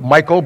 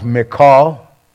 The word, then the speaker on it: Michal: I know some people pronounce it Michael, but I'd hate to have my wife called Michael. So I believe it's pronounced Michal, the wife of his youth.